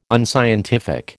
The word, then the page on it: unscientific: American English